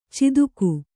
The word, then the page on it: ಚಿದುಕು: ciduku